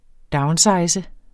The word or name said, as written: downsize